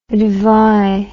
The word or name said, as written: dvi